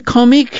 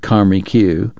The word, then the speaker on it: Comique: Let's go to the Comique, she suggested in French, pronouncing the theater's name properly, not the way the locals did, Comique.